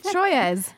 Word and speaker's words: Troyes: Troyes. – It's not a number. It's not. I'm not asking for 3. It's Troyes. The team Troyes. – I think it's just Troy, isn't it? – Troy.